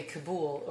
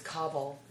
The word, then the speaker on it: Kabul: Yeah, no, when I was in Afghanistan, which was only a couple years ago, I, like in it's so funny, we always say Kabul over here, but it's Kabul. – Yeah. – That's how they say it.